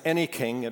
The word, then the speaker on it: Anyking: My last name is Anyking. It rhymes with anything.